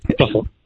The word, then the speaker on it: Suffolk: It's Suffolk. – Suffolk. – Suffolk County. – It's not Falk. And you want to say Falk when you look out how it's spelled Suffolk.